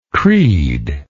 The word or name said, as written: creedlessness